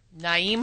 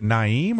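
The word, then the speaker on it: Nyheim: Now, is it Naeem or Naheem? – Money. – Now, receive. – Let's get to the bottom of this. – Receiving is Naeem. Wide receivers. – It's a silent H? – I think so.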